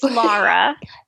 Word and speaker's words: Lara: It's Lara. – It's Okay. But is that Laura with the U? No, it's not Laura.